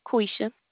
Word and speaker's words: Quesha: Quisha. – Can you spell that for me, please? – Q-U-E-S-H-A. – Q-U-E.